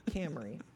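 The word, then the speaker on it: Kamerie: Like it's pronounced like the car, but it's K-A-M-E-R-I-E, Camry.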